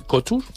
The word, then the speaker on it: Couture: Y Josep Perdomo, del Hierro, con la marca Perdomo Couture, ¿cómo se dice? – Perdomo Couture. – Couture. Ah, qué bien, tú hablas mejor que yo.